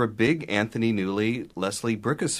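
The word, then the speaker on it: Bricusse: You know, he generally wrote the music, and Mr. Bricus, that's how you pronounce it, Bricus wrote the lyrics.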